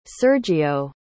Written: sergio

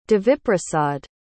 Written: deviprasad

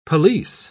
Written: pelisse

